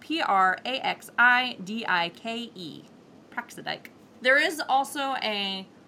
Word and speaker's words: Praxidike: Um, so Praxidike was the one who made sure that those punishments uh, were and that vengeance was properly enacted.